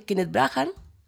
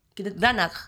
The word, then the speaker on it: Kenneth Branagh: Por ejemplo, destaca el villano, que es Kenneth Branagh. ¿Lo pronuncié bien? Mal. – Kenneth Branagh.